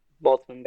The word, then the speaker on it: Boltzmann: Like you can't avoid the X risk of what if you're a Boltzmann brain? Is that how it's pronounced? – Yeah. Boltzmann brain.